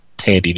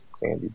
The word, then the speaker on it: Thandie: The H is silent, sir. But anyway, go ahead. – So, what is it? – Thandie Newton. – Thandie Newton. Oh, I always pronounce it as Thandie, but I mean, she can get it.